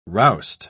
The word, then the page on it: roust: British English